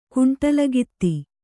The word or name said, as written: ಕುಂಟಲಗಿತ್ತಿ